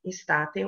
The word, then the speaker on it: Estate: And the surname sounds funny, but it's actually Italian. It means summer, and it's pronounced estate, which is interesting.